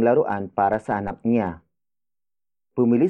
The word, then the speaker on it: bumili: Bumili si Kuya Rem ng laruan para sa anak niya.